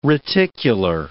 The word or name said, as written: reticular